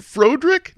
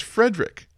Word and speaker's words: Frederick: Is it Frodrick? No, it's Frederick.